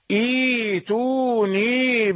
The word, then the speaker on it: ائتوني: فإذا كان الأمر كذلك فإن الهمزة الثانية تسهل، فإذا بدأت بالأولى تنطقها وتسهل الثانية تقول إيتوني بكتاب.